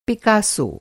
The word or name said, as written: पिकासो